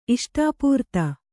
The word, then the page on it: ಇಷ್ಟಾಪೂರ್ತ: iṣṭāpūrta